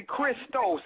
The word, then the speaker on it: Christos: It's actually Christos. It's the Greek form of Christ.